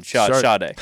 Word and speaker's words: Sade: There's no R in the correct pronunciation. Sade. – It's Sade. – Sade. – It's not Shah.